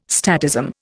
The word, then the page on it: statism: Indian